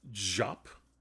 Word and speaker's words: Diop: I've been calling her last name Diop, but someone just told us in a letter that it's Jop.